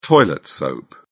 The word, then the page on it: toilet soap: British English